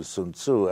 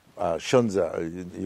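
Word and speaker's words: Sunzi: You know, not only Shunzi, the famous Sun Tzu, Sun Wu, Sun Tzu, as you pronounce him, Shunzi usually pronounced, but also a whole body of classics.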